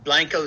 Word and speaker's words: Blanco: Well, it's, I know it's spelled Blanco, but in Texas, it's an older city and everything, but the Texas Batman said Blanco. – Gotcha. – And so Blanco stuck. So gotcha. And Texans know that, but I even have friends go, oh, is she in Blanco? And I go, no, it's Blanco. You know.